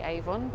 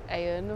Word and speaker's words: Avon: Look, I always say this wrong. I say Avon, but it's Aeon or something. I think that's it.